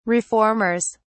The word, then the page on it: reformers: British English